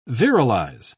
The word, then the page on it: virilize: British English